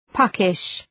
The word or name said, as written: puckish